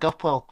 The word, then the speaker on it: Gupwell: So LinkedIn is Matt Gupwell.